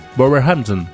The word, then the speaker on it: Wolverhampton: pero pronunciado es Wolverhampton.